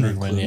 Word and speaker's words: Krugman: You saw guys like, I always say his name wrong, Paul Klugman or Krugman. – Krugman, yeah.